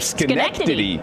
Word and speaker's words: Schenectady: Pronunciation of Schenectady.